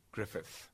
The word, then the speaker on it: Gruffudd: So the name David, spelled D-A-F-Y-D-D, has a the sound. Or the actor Ewan Griffith, his last name is spelled G-R-U-F-F-U-D-D, Griffith.